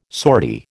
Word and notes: sortie: American English

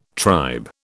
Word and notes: tribe: British English

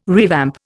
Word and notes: revamp: Indian

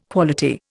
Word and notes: quality: Indian

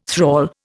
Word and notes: thrall: Indian

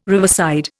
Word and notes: riverside: Indian

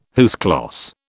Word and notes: à huis clos: British English

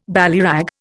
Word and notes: ballyrag: Indian

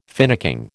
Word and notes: finicking: American English

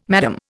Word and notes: Mme: Indian

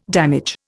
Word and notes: damage: Indian

